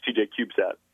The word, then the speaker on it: TJ3SAT: And if you just Google TJ3SAT, and it's pronounced TJ CubeSat.